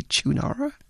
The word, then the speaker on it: Choonara: Am I pronouncing your name correctly, Choonara? – Yes, spot on.